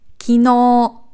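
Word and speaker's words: Kino (きのう): Eins von meinen Lieblingswörtern, weil es so oft falsch ausgesprochen wird. Kino. Kino. Gestern. Ist nicht Kino.Ja, wir sind im Deutschen sehr oft verleitet, irgendwie die erste Silbe zu betonen, aber das ist eher selten der Fall. Kino.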